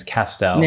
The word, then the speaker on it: Castell: And I've told you this, it rhymes with pastel. And my great-grandfather was a painter. So now you can kind of connect Castell to pastel and then you have the pronunciation.